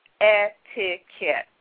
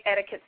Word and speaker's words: etiquette: And since the word etiquette comes from an old French word meaning ticket, a good way to remember how to pronounce the word is etiquette. When you say etiquette slowly, you actually hear yourself say ticket.